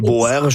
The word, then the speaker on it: Boers: Je suis jamais trop sûre de la prononciation, mais ça se déroule durant la guerre des Boers. – Oui, le Boer War en anglais, les Boers, je pense en français, oui, on le dirait ainsi.